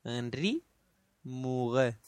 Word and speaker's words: Henri Murger: Henri Murger.